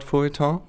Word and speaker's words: Feuilleton: Siehst du, das ist schon ein Wort, wo ich benutze das Wort Feuilleton. Mhm. Habe ich es richtig ausgesprochen? – Das war, glaube ich, richtig.